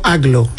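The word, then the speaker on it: Agloe: No, entiendo que Aglo, yo lo he escuchado pronunciado como Aglo.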